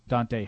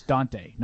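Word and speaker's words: Dont'a: I've been told, by the way, that you are mispronouncing Donta Hightower. It's Donta, not Donta.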